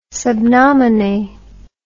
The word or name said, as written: sub nomine